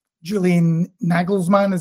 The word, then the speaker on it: Julian Nagelsmann: When I looked at the quote, just, and it wasn't long before he moved to Rangers, Julian Nagelsmann, is that the right pronunciation? – Mm-hmm.